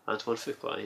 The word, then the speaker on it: Antoine Fuqua: Antoine Fuqua, yeah.